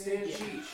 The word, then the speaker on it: Stancic: It's Stancic.